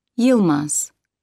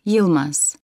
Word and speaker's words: Yilmaz: My name's Yilmaz. Hava Yilmaz. – How do you spell that? – Y-I-L-M-A-Z.